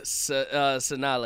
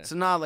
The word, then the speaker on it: Sanaa: Sanaa Lathan. – Sanaa Lathan.